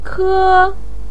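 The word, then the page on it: ke: tone 1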